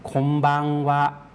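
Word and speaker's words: ค ม บัง ว ะ (こんばんは): คมบังวะคมบังวะครับทั้งตัวคมทั้งตัวบังนะครับออกเสียงยาวนะครับส่วนว่านั้นออกเสียงสั้นนะครับ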